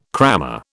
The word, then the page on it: crammer: British English